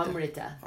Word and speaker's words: Amrita: Amrita. – Amrita. – Amrita, yeah, long A.